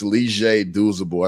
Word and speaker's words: Leger Deuceable: And a lot of people look at my last name and think it's more challenging, but I think my first name, just because it doesn't sound how it's spelled, right? It's Leger Deuceable. I think Deuceable is a lot easier when you look at it phonetically on paper to say than Leger.